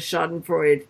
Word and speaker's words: Schadenfreude: Anyway, no, Big Jim Murray always says Schadenfreude.